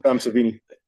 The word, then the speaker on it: Savini: Tom Savini.